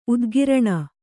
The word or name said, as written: ಉದ್ಗರಣೆ